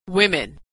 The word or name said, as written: women